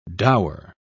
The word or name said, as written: dower